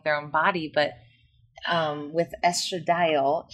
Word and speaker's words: estradiol: Is that how you say it? Estradiol? – Estradiol. – Estradiol.